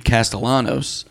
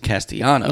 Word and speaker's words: Castellanos: Because he said it should be Castellanos. He's like, but they pronounce it Castellanos. – No, it's Spanish.